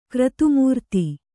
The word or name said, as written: ಕ್ರತುಮೂರ್ತಿ